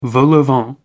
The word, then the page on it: vol-au-vent: American English